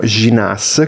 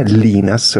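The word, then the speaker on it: Llinás: che si chiama Mariano Ginas, credo si pronunci in argentino, ha la doppia L iniziale, Linas, noi lo leggeremmo in italiano,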